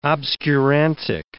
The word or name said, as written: obscurantic